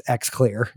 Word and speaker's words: XClear: Or I don't know if there's some kitschy pronunciation for it, but it's XClear is what it is.